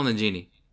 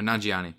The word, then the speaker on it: Nanjiani: Oh, it's Kumail Nanjiani. Or Nanjiani. I said that wrong.